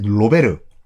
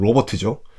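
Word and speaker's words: Robert: 어, 아버지 그 선왕이 이름이 이제 로베르. 그, 그 영어식 발음은 로버트죠.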